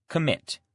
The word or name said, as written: commit